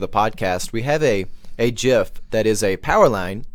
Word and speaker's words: GIF: Is it just me, or can you hear this GIF? – And I do like that pronunciation with the hard G there, just to get that one out there on the topic of language.